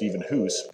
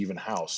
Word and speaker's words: Evenhuis: If you were in Holland, it would be pronounced even Hoos. But when my parents came to America, they wanted to Englishize it, I guess you would say. So the approximation would be Even House.